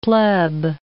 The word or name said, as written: pleb